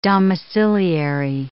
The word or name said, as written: domiciliary